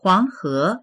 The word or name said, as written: huang2he2